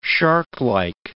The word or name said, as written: sharklike